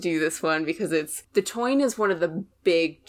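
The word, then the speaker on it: Táin: It looks like the tain, T-A-I-N, is how it's spelled, but it's the toine.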